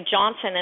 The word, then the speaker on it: Jonsson: Melissa Joy Jonsson, and that's spelled J-O-N-S-S-O-N.